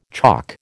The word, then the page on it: chock: American English